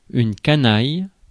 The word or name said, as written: rascal